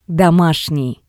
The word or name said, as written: domashnii